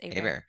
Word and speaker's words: Hebert: Did I say that right? – It's Hebert.